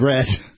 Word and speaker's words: read: He also spelled red. – The past tense of read is red, which is spelled the same as read. – No, it's not, because the A is silent.